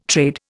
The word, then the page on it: trade: Indian